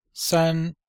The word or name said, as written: san1